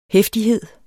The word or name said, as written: heftighed